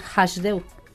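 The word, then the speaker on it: Hasdeu: Hablamos de Hajdeu. – Hajdeu. Es que nosotros pronunciamos la H como la J aquí en España. – Ah, bien. – Y empieza por H, es decir, Hajdeu.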